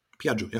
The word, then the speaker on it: Piaggio: Piaggio, yeah.